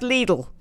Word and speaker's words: Lidl: Okay, but Lidl is pronounced Lidl. Didn't know that, did you? – Lidl. – Lidl. – Yes, because German.